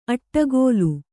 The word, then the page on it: ಅಟ್ಟಗೋಲು: kannada; aṭṭagōlu